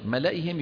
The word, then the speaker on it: ملئهم: على خوف من فرعون وملئهم، ملئهم يقال فيها ما قيل في ملئه. انتبهنا أن الياء هذه إيش؟ زائدة في الخط، تكتب ولا تلفظ.